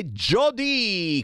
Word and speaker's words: Jody: E a riproporre questo pezzo è Jody con la J e l'accento sulla I, che non è una I ma è una Y.